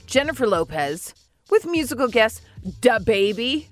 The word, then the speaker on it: DaBaby: DaBaby. – That was pretty good. – I mean, I don't... She put a lot less emphasis on duh.